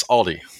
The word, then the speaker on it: Aldi: It ends in a vowel. Adding uh-huh. an S is an accepted mispronunciation, and I'm going to die on that hill. All right. All right. So yes, Aldi.